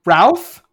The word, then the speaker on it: Routh: Oh, how'd you pronounce his name? Uh, Ruth, R O U T H. Brendan Ralph. Ralph. Ralph. Yeah. – Huh. What else is he in? – I thought it was uh, Ruth.